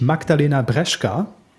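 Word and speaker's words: Magdalena Brzeska: Eine echte Olympionikin haben wir auch noch am Start, nämlich Magdalena Breschka. Hoffe, dass der Name zumindest so richtig ausgesprochen ist. Ja, hast du komplett richtig ausgesprochen, ja.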